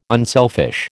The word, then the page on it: unselfish: American English